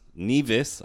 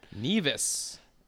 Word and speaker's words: Nevis: Nevis, I learned last night, is how you pronounce it. – Nevis.